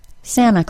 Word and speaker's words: Santa: In the name Santa, we've got a letter T that follows an N sound and comes before a vowel sound. If you've been studying your T sound allophones, you might recognize that this is a place that the T sound can be dropped. Therefore, you'll often hear Santa called Santa Claus. Santa is a perfectly acceptable pronunciation.